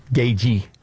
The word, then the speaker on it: Gaethje: So Joe Rogan is doing an interview with UFC fighter Justin Gaethje, Gagey is how you pronounce it. The TH is silent.